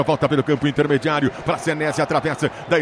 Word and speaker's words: Senesi: Yuri Queiroga, você que é o nosso Yuri Pedia, a gente vai de Marco Senesi ou Senesi, a pronúncia correta? – Senesi, Senesi. Senesi, então o narrador estava falando certinho, né? – E o Anderson também.